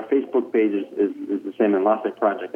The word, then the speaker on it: Enlace: Enlace spelled, for those who might not know, E-N-L-A-C-E. – E-N-L-A-C-E. C-E. – Yeah. And then like just... enlace is how you say it. And enlace in Spanish means connections or link, which is kind of what we do.